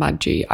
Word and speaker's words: mudgy: Funny that when you said when it was hot and mudgy, I thought you were going to say it was hot and muggy but mispronounced it. – It's a soft G. Mudgy.